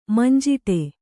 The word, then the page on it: ಮಂಜಿಟಿ: Kannada